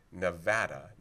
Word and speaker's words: Nevada: In this case, people were saying the official pronunciation should be something English, American, non-Spanish, that it's Nevada, not Nevada. Now, the state of Nevada, usually everyone there, as far as I understand, pronounces it Nevada.